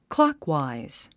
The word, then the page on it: clockwise: klɑ́kwàiz; クロォクワァイズ